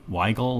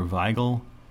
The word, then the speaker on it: Wiegel: This version was made by this guy, Peter Weigel or Weigel. Do I have the... Yeah. Yeah. W-I-E-G-E-L. What's that? Weigel or Weigel? I don't know. I'll say Peter Weigel. Because I pronounce W as woo.